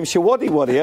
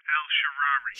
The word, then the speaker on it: El Shaarawy: He called him Shawadi Wadi earlier. – What an idiot. Not being able to pronounce the name right. Everyone knows it's Shawinny Winnie. – It's Stefan El Shaarawy.